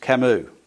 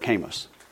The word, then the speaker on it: Camus: Now, the guy's name's pronounced Camus, okay? But I had this English professor who had this little ditty that he used to say all the time, which was, "Oh, to be known, oh, to be famous, oh, to be quoted," like Albert Camus, which is not the way you pronounce his name.